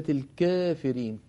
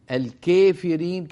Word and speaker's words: الكافرين: ك ك هذه الكلمة ورش يقللها، يعني يميلها إمالة صغرى، يعني يميلها بين بين، لا يميلها إمالة كبرى. الكافرين كما نطقتها أنت هذه يفعلها غير ورش، يفعلها أبو عمرو البصري والدوري عن الكسائي، يقولون الكافرين ك إمالة كبرى، أما ورش يقول ك ك.